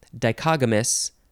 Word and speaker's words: dichogamous: Dichogamous is how you would pronounce that.